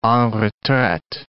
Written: en retraite

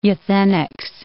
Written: euthenics

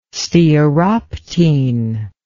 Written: stearoptene

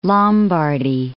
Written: Lombardy

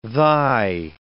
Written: thy

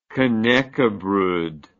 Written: knackebrod

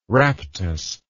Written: raptus

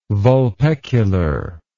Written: vulpecular

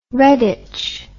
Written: redditch

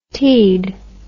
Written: teaed

